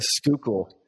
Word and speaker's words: Schuylkill: Schuylkill.